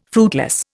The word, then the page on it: fruitless: Indian